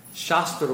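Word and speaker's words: Shastra (शास्त्र): Shastra with a long A means scripture. Shastra with a short A means weapons.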